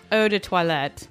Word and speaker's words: Eau de Toilette: Yeah, like it's Eau de Toilette. – Eau de Toilette. So can we call this Eau de Toilette? – I mean, no, we can't.